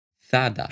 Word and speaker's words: Zara: Zara, und ich bleib dabei, wird tatsächlich Zada ausgesprochen.